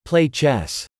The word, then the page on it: Play chess: American English